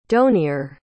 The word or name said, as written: donier